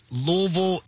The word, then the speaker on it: Louisville: Y'all aren't saying Louisville, Louisville. It's Louisville, and I'm so happy and so thankful Louisville, that y'all are saying Louisville, it correctly.